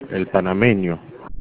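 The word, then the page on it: panameño: Spanish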